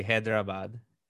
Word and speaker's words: Hyderabad: Well, so it's named after Hyder. And so that would be, in that sense, it would be Hyderabad.